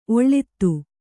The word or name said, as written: ಒಳ್ಳಿತು